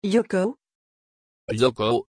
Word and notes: Yoko: French

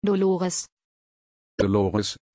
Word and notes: Dolores: German

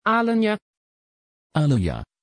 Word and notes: Alenja: Dutch

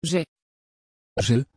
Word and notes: Zen: Dutch

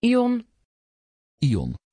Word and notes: Yon: Dutch

